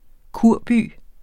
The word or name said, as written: kurby